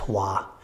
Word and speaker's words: Hoa: H-O-A is his first name, pronounced Hua.